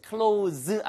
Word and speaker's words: close: 그러면 C-L-O-S-E의 발음만 주의하세요. close 아니고 close 하면은 가까운 거죠.